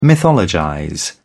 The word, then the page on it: mythologize: British English